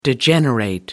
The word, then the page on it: degenerate: American English